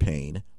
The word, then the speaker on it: Payne: Cleveland Payne.